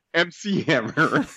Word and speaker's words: MC Hammer: I like Mick Hammer. He's like, did you call him Mick Hammer? So I'm like, what's his, how do you say it? He's like, MC Hammer, not Mick Hammer.